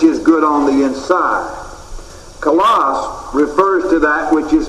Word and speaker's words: kalos: Two of the chief Greek words which are translated good are the words agathos and kalos. Agathos is spelled A-G-A-T-H-O-S. And here's the Greek equivalent of it, A-G-A-T-H-O-S. The other word is kalos, K-A-L-O-S.